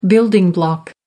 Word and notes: building block: American English

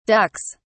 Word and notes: ducks: British English

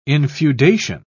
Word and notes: infeudation: British English

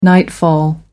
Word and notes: nightfall: American English

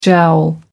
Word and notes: jowl: American English

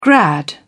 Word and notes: grad: British English

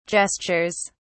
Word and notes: gestures: British English

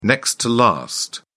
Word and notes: next-to-last: British English

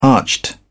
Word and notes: arched: British English

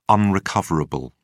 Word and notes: unrecoverable: British English

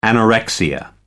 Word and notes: anorexia nervosa: American English

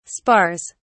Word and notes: spars: British English

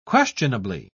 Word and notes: questionably: British English

